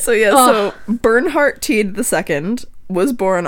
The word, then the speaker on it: Bernhardt: It's like heartburn, but it's Bernhard. – How do you spell it? – B-E-R-N-H-A-R-D-T. – R-D-T.